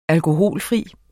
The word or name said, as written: alkoholfri